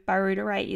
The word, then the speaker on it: butyrate: So it's spelled B-U-T-Y-R-A-T-E. So butyrate is how I say it.